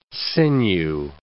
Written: sinew

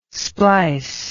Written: splice

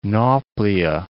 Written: Nauplia

